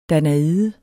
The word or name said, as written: danaide